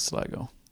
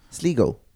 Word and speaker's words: Sligo: And it's Sligo. – Sligok. Or is it say? Or is Satnav say? Sligo.